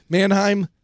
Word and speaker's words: Manheim: So if you remember on Friday, it was Patrick Dempsey versus Milo Manahim. Am I saying his name correctly? – Manheim.